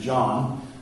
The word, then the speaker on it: Jan: And so John Huss, and his name is spelled like Jan, like from the Brady Bunch, Jan, but his name is John.